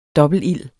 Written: dobbeltild

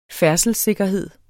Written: færdselssikkerhed

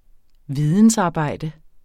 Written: vidensarbejde

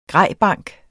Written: grejbank

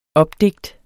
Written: opdigt

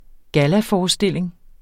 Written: gallaforestilling

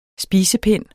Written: spisepind

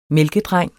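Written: MÆLKEDRENG

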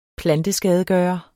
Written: planteskadegører

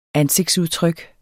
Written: ansigtsudtryk

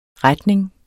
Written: retning

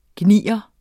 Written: gnier

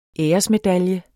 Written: æresmedalje

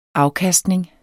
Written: afkastning

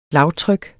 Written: lavtryk

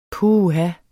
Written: puh

